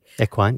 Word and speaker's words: equine: You can do, I'm going to say this wrong, equine therapy. – Equine, yeah. – Equine, with thank horses.